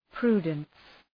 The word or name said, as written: prudence